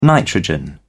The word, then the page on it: nitrogen: British English